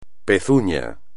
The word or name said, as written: pezuña